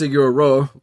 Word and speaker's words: Sigur Rós: And I got a very big, what I've come to find out is pronounced Siguro, Siguro's feeling.